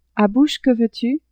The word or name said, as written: à bouche que veux-tu